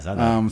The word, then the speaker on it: Zadar: And located in Zadar. – Yeah, Zadar.